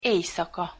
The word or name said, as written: ejszaka